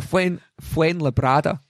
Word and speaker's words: Fuenlabrada: I've been putting an N in where there wasn't an N. So Fuenlabrada have won.